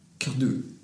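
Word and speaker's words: Cardouat: Som, eh, Marie, hur uttalas hennes efternamn? Eh, Cardois. – Hon har nyss varit i Frankrike och kan inte uttala det här. Eh, förlåt, hon, eh, det, det uttalas Marie Cardois. Cardu. Perfekt. Cardois.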